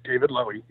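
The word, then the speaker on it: David Lowy: And if I could talk about a little more about the Dead Daisies is, you know, it's interesting that so Dave Lowey, am I pronouncing that right? David Lowey? – David Lowey, yeah.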